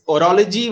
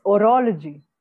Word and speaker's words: horology: I mean, it's horology with the H is sort of silent. – Oh, okay. It's horology.